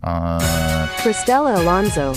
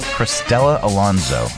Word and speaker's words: Cristela Alonso: Cristela Alonso. – Cristela Alonso.